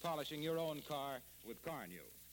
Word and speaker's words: Car-Nu: You can get Johnson's Car New right now at your dealers. It's spelled C-A-R-N-U.